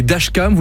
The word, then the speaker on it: dashcam: C'est ce qu'on appelle des dashcams.